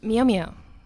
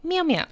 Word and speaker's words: Mjolnir: We've got Maria Lewis, who knows what a Mjolnir is and will tell me how to pronounce it properly. – Mjolnir. Obviously. If you'd seen Thor the Dark World, the cinematic classic, you would know it's pronounced Mjolnir.